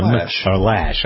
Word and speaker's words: Loesch: Dana Loesch is one of them. – Lash. – I always mispronounce her name. Why doesn't she change the spelling of it? So it's L-O-E.